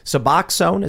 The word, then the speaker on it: Suboxone: Suboxone, is that how you pronounce it? – Yep.